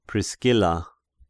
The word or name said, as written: Priscilla